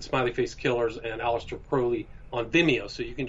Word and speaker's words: Crowley: The proper pronunciation is Crowley, but...